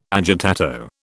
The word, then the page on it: agitato: British English